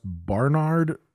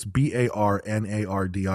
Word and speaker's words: Barnard: She goes to I don't know if it's pronounced Bernard. It's Barnard. I always see it in print.